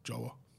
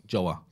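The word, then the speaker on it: João: But I was like, how the fuck do we say this? And he was like, Joa. – Joa.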